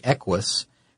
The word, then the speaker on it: Equus: And the reason Hyundai was invited to the table is because of their, at the time, brand new model, which was the Equus. And I think I'm hoping I'm saying that right. It's a weird name. It's EQUUS, I think. – Yeah, you're saying it.